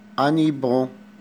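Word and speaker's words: hippo: صورة رقم ستة سيتانيبو، طبعا حرف الاش لا ينطق، انيبو.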